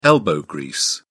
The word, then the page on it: elbow grease: British English